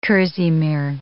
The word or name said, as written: kerseymere